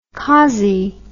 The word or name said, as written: khazi